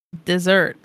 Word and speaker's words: Desert: But when I looked on locations in Maine, how to say them, apparently you're supposed to call it Desert.